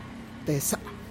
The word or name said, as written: teza